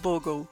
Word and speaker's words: Bogle: Janeiro é o mês do santo padroeiro dos investidores amadores, o John Bogle, é assim que se pronuncia? – E, John isso, Bogle. Isso, Bogle.